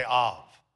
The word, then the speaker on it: Av: Everybody say Av.